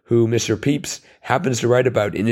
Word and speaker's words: Pepys: And the author, who presumably would be up on all the correct pronunciation, pronounces it Samuel Pepys.